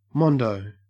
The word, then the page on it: mondo: Australian English